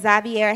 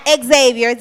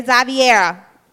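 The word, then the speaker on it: Xaviera: Okay, so you can find me as Zaviera Handsome. My name is spelled, you listening to me, it's spelled X-A-V as in Victor, E-R-I-A, okay? Not Xavier, Zaviera.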